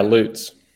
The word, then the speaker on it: Lutz: Yeah, Lutz.